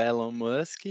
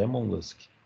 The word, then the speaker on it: Elon Musk: Mas aquela galera que não é afim do, nem do Mark Zuckerberg, nem do Elon Musk, encontra o Ed 3 aonde? – Não, você tá falando errado, é Elon Musk.